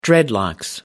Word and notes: dreadlocks: American English

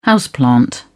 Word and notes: houseplant: British English

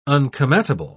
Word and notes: un-come-at-able: British English

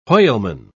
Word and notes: whaleman: British English

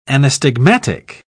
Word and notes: anastigmatic: British English

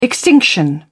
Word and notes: extinction: British English

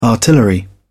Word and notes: artillery: British English

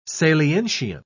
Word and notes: salientian: British English

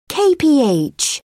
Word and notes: kph: British English

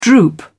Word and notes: droop: British English

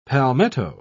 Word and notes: palmetto: British English

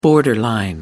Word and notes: borderline: American English

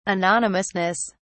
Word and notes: anonymousness: British English

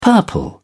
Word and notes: purple: British English

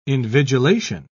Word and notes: invigilation: British English

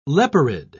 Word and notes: leporid: British English

